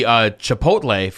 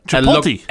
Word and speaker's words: chipotle: The chipotle flavored one. – Chipotle.